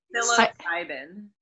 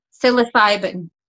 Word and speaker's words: psilocybin: Psilocybin. – Psilocybin.